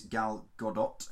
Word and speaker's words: Gadot: Yeah, in the lead role, if you've been living under a rock, you will be unaware, the lead actress is Gal Gadot. I believe the T is pronounced. – The T is pronounced, yes.